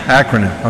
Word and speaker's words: acronym: So it's an anacronym. So how did I say it right? – No. – I still didn't say it right. Acronym. – Acronym. – Okay. It's an acronym.